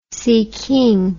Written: siking

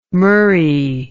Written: murry

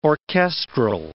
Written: orchestral

